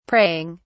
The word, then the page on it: praying: British English